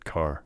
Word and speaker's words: Kerr: She spells her name K-E-R-R, but pronounces it Carr.